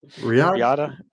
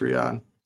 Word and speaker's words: Riyadh: So this took place November 2nd, 2018 from, you're going to have to check me on the pronunciation here. The other one was Jeddah. This is Riyadh. – Riyadh? Was it Riyadh? – Yeah, just Riyadh.